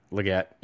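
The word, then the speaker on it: Legette: Is it Xavier Leggett or how do you pronounce that properly? – I think it's Leggett. Yeah.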